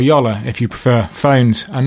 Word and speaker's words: Jolla: And she told me the Finnish word is pronounced Yolla. That it means like boat. It's like a small boat,